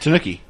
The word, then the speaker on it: tanuki: The tanuki suit. Is that how you say it? – Tanuki.